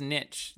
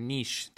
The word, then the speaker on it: niche: Um, and I really I saw this niche that or niche, depending on how you want to pronounce that.